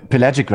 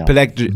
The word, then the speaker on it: Pelagic: wie wird das ausgesprochen? P Pelagic Records, genau, ja. Pelagic.